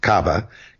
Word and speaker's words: Kaaba: And the Kaaba, which is usually pronounced in the, the news as just Kaaba, Kaaba it's uh, between the K A apostrophe A B A, which is the shrine in the middle of Mecca, which people process around when they go on the Hajj.